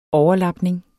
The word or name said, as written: overlapning